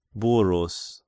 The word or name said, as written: Burrus